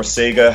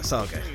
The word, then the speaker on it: Saga: I'm of course into Saga or Sega, however you want to pronounce it. – Saga. – Yeah. Sure. Let's go with that.